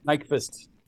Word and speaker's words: Nykvist: Nykvist.